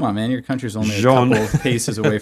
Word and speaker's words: Jean-Jacques Rousseau: Today you are talking about, apparently, Jean-Jacques Rousseau. – That is not how you pronounce it.